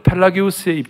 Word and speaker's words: 펠라기우스: 하나는 펠라지우스, 펠라기우스라는 사람이 있고, 미국 발음으로는 펠라지우스고, 한국은 펠라기우스라고 그러고, 어거스틴이 있었어요.